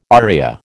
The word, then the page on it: aria: American English